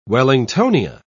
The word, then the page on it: wellingtonia: British English